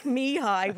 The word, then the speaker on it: Mihai: Again, like Mihai, where I wrote down M-E-H-I-G-H in order to pronounce it.